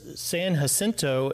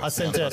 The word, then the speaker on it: San Jacinto: Great pronunciation on the name, by the way. – Jacinto. Thanks to your coaching. – Yeah, San Jacinto.